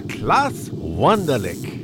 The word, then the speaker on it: Wunderlich: klaus wunderlich I ever say his name right? – I think you're fine.